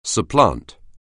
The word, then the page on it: supplant: anglais